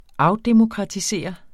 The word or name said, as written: afdemokratisere